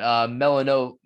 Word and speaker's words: Melifonwu: Yeah, D-end out of Georgia, and Jalen Phillips, D-end out of Miami, as well as Ifeatu Melanoff. Wait, there's a thing blocking it. Melifonwu. Yes, I am bad at pronunciations.